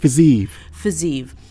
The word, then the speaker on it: Fiziev: Fiziev.